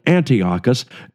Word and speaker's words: Antiochus: His name was Antiochus Epiphanes, Antiochus the Fourth, or some people put the emphasis on a different syllable and they call it Antiochus the Fourth Epiphanes.